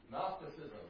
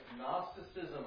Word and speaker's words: Gnosticism: Gnosticism, that's right. Starts with a G, but doesn't help because the G is silent. Right? Gnosticism.